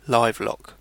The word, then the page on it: interblocage: Paris; /ɛ̃.tɛʁ.blɔ.kaʒ/